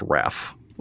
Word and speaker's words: Hrafn: It's actually spelled H-R-A-F-N, but it's pronounced Raff.